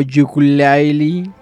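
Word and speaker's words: Yooka-Laylee: Sin embargo, con yooka o Yukulaili, como dice los que se pronuncia como Ukulele Ukulele, Ukulele, así.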